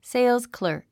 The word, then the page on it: salesclerk: séilzklə̀ː r k; セイルスクラーク